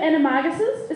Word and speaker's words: Animagus: But they have animaguses. Is that how you say it? Animaguses. – Animagus, thank you. Which the plural is animagi.